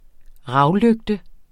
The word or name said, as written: ravlygte